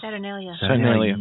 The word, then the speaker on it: Saturnalia: So they're looking for a holiday to compete with the Roman holiday of, I always pronounce this wrong, but I want to say Saturnalia. – Saturnalia.